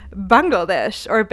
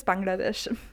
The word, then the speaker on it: Bangladesh: And it is, drumroll please, Bangladesh, that's the English pronunciation. In German, it's Bangladesh.